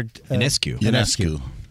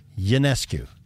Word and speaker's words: Ionescu: Ionescu. – Ionescu? – Yes. It's like a Y. – Ionescu.